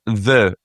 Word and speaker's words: Ye: Beautiful pubs like Ye Olde Reindeer Inn, as peasants pronounce it, because it's not meant to be pronounced that. It's meant to be pronounced the Olde Reindeer Inn, which has is fitted out completely wainscoted in the Globe Room.